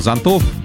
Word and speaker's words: зонтов: В значении оно означает навес от солнца, но не сохранило ударение. Поэтому зонтов, зонту, зонтам и так далее.